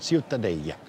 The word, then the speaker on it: Ciutadella: Aber Ciutadella, richtig ausgesprochen? – Ja, das Städtchen, Ciutadella. – Ja, ist schon besonders schön. – Ciutadella, ja.